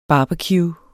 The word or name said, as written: barbecue